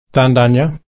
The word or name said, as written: tandanya